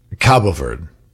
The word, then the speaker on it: Cabo Verde: I'm seeing, by the way, it's pronounced both ways. It's pronounced Cape Verde or Cape Verde, but the Portuguese pronunciation is Cabo Verde.